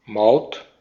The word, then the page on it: Maut: maʊ̯t; /maut/; German